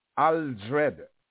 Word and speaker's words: Aldred: Bible name, T-I-M-O-T-H-Y, and my surname is Aldred, A-L-D-R-E-D.